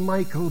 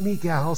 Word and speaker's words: Michal: Some pronounce her name Michael, but I pronounce it Michal so that you know it's a feminine name.